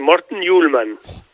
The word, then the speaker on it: Morten Hjulmand: ¿Cómo se pronuncia? ¿Cómo lo pronuncias tú? – Bueno, lo dijiste bien, Morten Hjulmand.